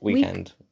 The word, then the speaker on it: Weeknd: How do you say it? – Weeknd. – But it doesn't have an E.